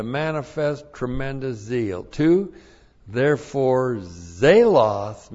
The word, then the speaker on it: zelos: We have the conjunction Allah, meaning even, and the accusative singular of zelos. Z, long E-L-O-S. Zelos.